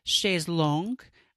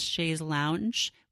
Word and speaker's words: chaise longue: But we now have a fainting couch, or if you prefer, a chaise longue, um, which is apparently the actual name. I always thought it was chaise lounge, which is how they say it in the States. But in the French, it's a long chair.